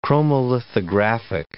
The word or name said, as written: chromolithographic